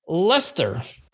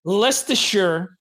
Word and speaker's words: Leicester: which is pronounced Leicester. I have been pronouncing it Leicestershire or, or for decades until oh Leicester Country is gonna win the Premiership. Leicester Country, who the hell are they? I never heard of them. And then I look at you know the standings. I'm like, oh, that's uh Leicester or whatever, and I'm like, ah, okay, so you're a dope. You've been pronouncing it wrong for all these years.